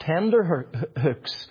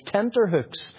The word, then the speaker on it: tenterhooks: It's not tender hooks, as I always thought it was. It's tenterhooks.